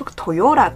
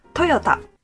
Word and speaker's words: Toyota: Japanese people pronounce it Toyota. – Toyota. Yeah, I'd say Toyota. So we're emphasizing the Y, Toyota, or that syllable, yo, instead of ta.